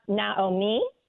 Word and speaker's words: Naomi: We're talking about Naomi today, and I didn't say that correctly. Say it one more time for me correctly. – Naomi. – Naomi.